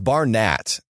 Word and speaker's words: Barnatt: It's Barnatt. – Oh, Barnatt. – I've always said Nathan Barnett. – You can say it like that. That's fine.